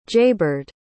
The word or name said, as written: jaybird